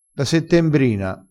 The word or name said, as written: settembrina